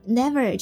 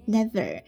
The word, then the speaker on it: never: 这里呢我要提一下这个 never 这个词的发音， never never 那个 V 是要咬住下嘴唇的，在精灵的版本中呢它唱成了 never，这样是错的。